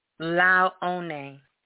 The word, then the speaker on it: Laone: L-A-O-N-E, Launie. – L-A-O-N-E. E? – Yeah. – La, say it. – Launie. – Launie. – Yeah. There you go.